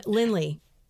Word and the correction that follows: Linnell: you talk about, am I saying her name right? – Oh, Linnell. – Linnell. Okay.